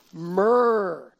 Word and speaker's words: myrrh: Okay, so let's move on to number three, myrrh. Myrrh. Myrrh is really interesting because it rhymes with gur, and who doesn't like to say gur once in a while?